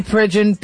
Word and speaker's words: Pridgen: Matthew Pridgen, P-R-I-D-G-E-N. Did I say that right? – You got it. – P-R-I-D-G-E-N.